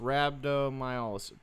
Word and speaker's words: rhabdomyolysis: Rhabdomyolysis? pronounced rhabdomyolysis.